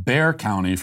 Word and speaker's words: Bexar: Matthew says, Bexar County is pronounced Bear County for future reference.